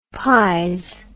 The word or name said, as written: pize